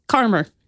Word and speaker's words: Caldmore: So it's spelled C-A-L-D-M-O-R-E. Caldmore is how I'm reading it. But apparently it's pronounced Carmer. Carmer Green.